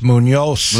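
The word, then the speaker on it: Muñoz: Yes, Munoz. – Munoz. – Dave Valle likes to call him, and I think he deserved that extra syllable. – The proper pronunciation there, by the way.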